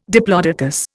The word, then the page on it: diplodocus: Indian